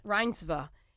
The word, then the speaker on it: Reinsve: Reinsve.